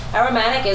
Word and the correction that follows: aromantic: No, I said that wrong. It's actually aromantic.